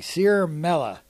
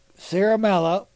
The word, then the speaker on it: Ciaramella: Let's see if I can pronounce this name correctly. Sierra Mella. So I'll spell it for you. C-I-A-R-A-M-E-L-L-A plotted with future Schiff staffer to take out the president as early as 2017.